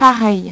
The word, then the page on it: pareil: French